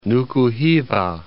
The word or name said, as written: Nuku Hiva